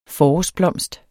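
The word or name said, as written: FORÅRSBLOMSTEN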